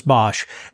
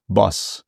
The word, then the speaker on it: Bosch: As I have heard this, is the correct pronunciation for his name Boss or Bosch or Boss? – Boss. – It is Boss. – In Netherlands, they would say Boss.